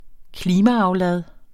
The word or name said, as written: klimaaflad